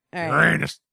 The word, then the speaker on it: Uranus: Uranus. – Say it right. Uranus. – Uranus.